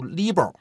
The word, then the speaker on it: LIBOR: 我有一年在外面上课，跟同学们讲这个伦敦银行间同业拆借读 LIBOR，然后呢，下了课有个学生跑过来跟我说老师你这个发音不对，应该读 LIBOR，它是海归。我想我这人知错能改啊，我到了下堂课我就读 LIBOR 了，然后后来呢读了 LIBOR 之后，下了课又有一个学生跑过来说他也是海归，应该读 LIBOR，